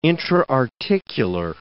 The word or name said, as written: intra-articular